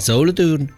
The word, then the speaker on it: Solothurn: Ma Soletta di... si pronuncia Solothurn? – Eh sì, in tedesco. – Davvero? – Sì, Solothurn.